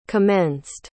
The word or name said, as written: commenced